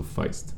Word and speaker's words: Feist: You're English speaking, so Feist.